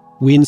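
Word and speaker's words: Wien: You can find Josh's music on Bandcamp by searching Ween Solo, that's spelled W-I-E-N-S-O-L-O.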